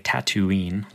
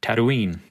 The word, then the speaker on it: Tatooine: So the locals uh, don't say Tatooine like we would, but Tatooine. It's like Toronto and Toronto.